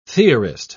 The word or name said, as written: theorist